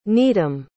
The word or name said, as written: needham